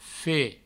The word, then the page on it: fe: Provençal